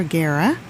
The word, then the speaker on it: Guerra: You know, I'm familiar with Jackie Starr through Bim Scala Bim, but she also fronted her own band, Contra Guerra. – Contra what now? – Guerra. G-U-E-R-R-A.